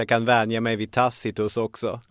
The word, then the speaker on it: Tacitus: Tacitus, ett namn som faktiskt uttalas just Tacitus av expertisen oavsett hur fel det känns.